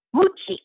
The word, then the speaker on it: Mucci: Mucci.